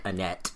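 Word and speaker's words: Anet: In the lands of Annette, or Anne. – Is it Anne? I guess it would be, because the T is silent in French. – Just, if you're doing your own research, it's A-N-E-T, like Annette.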